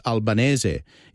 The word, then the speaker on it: Albanese: It's billion with a double L and his surname is pronounced Albanese.